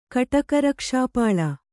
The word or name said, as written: ಕಟಕರಕ್ಷಾಪಾಳ